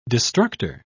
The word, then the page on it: destructor: British English